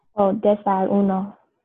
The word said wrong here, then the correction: desayuno: No, es desayuno, es desayuno.